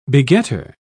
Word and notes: begetter: British English

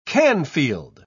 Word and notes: canfield: British English

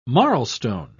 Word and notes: marlstone: British English

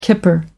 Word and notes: kipper: American English

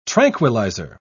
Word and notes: tranquilliser: British English

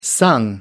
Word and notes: sung: American English

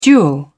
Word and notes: duel: British English

